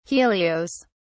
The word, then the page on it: helios: British English